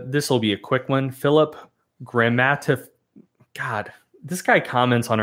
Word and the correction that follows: Grammatikovsky: I should know how to pronounce his name. It's so many syllables. – Grammatikovsky. – I think that's correct. Grammatikovsky.